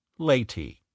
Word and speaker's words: Leyte: nor even how to pronounce it. Leyte.